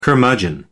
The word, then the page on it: curmudgeon: American English